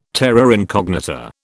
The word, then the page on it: terra incognita: British English